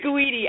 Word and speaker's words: Guidi: Did I say that right, Alicia? – It's Guidi, actually. Close enough.